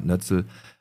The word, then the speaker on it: Nötzel: Heute gegen den Alex tritt an der David Nötzel, auch bekannt als Proton. Richtig ausgesprochen? – Ja.